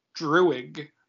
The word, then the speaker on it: Druig: How is it pronounced? – Druig. Not Droog. – Whatever. – You see the I in there, right? – Yeah, whatever.